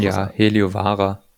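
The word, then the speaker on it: Heliövaara: Herren Doppel haben Patton Heliovaara, habe ich das eigentlich richtig ausgesprochen? Ich will jetzt Heliovaara. Ja. Also man muss jetzt nicht tief ins Finnische eintauchen, aber nee.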